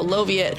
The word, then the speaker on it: Loviot: She says it's pronounced Soviet, but with an L. So there you go, Loviot.